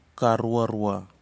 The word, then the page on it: karuarua: kwamera